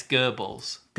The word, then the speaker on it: Goebbels: I'm reading, everyone's talking about how to pronounce Gables. Yeah. And apparently it's Goebbels.